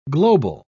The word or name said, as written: global